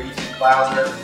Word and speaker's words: Ethan Clouser: I got my brother Ethan Clouser with me here today.